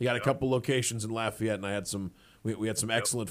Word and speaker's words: Lafayette: And thanks for pronouncing it the correct way. It's not Lafayette like it is in Alabama.